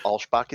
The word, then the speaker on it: Alschbach: I'm so impressed that you got the pronunciation of my name, and then I couldn't say pronunciation. Beautiful. Solid start. – Welcome, solid Matt. Start. – I studied. – No. Yeah, Alschbach is correct.